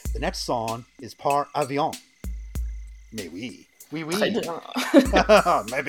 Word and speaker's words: Avion: So with that, we will now crossfade, just like the album does, into Nature Sounds and Par Avion, or Avion, however you want to pronounce things. I'll say Avion. – It's definitely Avion, if you want to know.